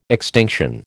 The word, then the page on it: extinction: American English